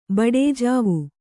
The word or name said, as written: ಬಡೇಜಾವು